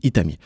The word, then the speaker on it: 1T4M1: épelé 1T4M1, prononcé, comme je l'ai dit, Itami.